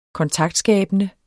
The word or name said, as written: kontaktskabende